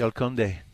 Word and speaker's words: El Conde: El Conde.